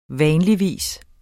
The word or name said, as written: vanligvis